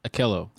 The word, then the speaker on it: Akello: I got to remember how you say it. – Akello. – I said it right.